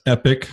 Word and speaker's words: epoch: I mean, you know, E-P-O-C-H, right? If you grew up in good Bible thumping churches, you probably heard epoch, especially if it was King James. But it is pronounced epic.